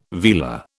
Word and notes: vela: British English

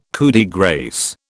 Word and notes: coup de grâce: British English

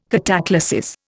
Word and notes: cataclasis: Indian

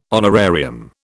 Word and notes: honorarium: British English

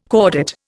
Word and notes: cordate: Indian

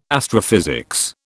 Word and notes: astrophysics: British English

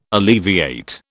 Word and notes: alleviate: British English

